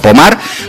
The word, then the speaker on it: Pomard: Si las buscáis, sí, Pomard, se escribe Pomard, como si fuera en castellano, pero Pomard, pues allí en Francia, hablamos de unas imágenes que podían pasar.